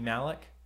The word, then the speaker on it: Malek: It's got Denzel and Rami Malek.